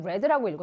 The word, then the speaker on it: read: 앞에 이 read가 아니라 뭐라고 읽어준다? Read. 과거형 read라고 읽어준다.